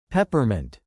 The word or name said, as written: peppermint